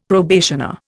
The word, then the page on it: probationer: Indian